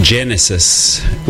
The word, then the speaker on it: Genesis: Genesis, with the accent on the first E.